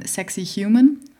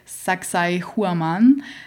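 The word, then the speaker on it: Sacsayhuamán: Sexy Human. Ähm, in Wirklichkeit spricht man es aus wie Saksai Huaman.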